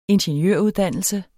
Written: ingeniøruddannelse

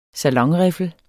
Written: salonriffel

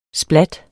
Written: splat

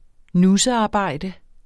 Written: nussearbejde

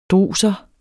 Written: druser

